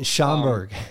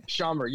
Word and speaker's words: Schaumburg: Schaumburg, Illinois. – Oh, yeah, Schaumburg. – Schaumburg, yeah.